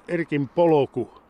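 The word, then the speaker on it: Erkin polku: Kuinka paljon tuo Erkin poloku, niinhän se lausutaan, niin tuota, Kuinka paljon se vaatii ylläpitoa?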